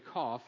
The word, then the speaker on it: Koph: Now, I like to say Kof because that's how it looks to me, K-O-P-H, but some translations will have Q-O-F, but it's pronounced Kof in the Hebrew.